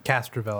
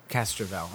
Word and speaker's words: Castrovel: How is it pronounced again? Castrovel? – Castrovel, I believe. – Castrovel.